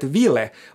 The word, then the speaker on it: ville: och Fabian skriver också inom ett annat ord som, som jag tycker är jättespännande och det är ordet ville. Båda vokalerna ska uttalas kort säger Fabian här.